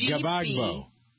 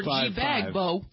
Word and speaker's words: Gbagbo: Wikipedia says Gbagbo. – Without this, a silent G? – Looks like it.